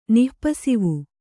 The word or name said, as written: ನಿಃಪಸಿವು